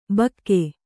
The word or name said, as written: ಭೋಕು